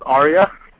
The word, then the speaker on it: Arya: It's pronounced Aria. – Aria. Okay, cool.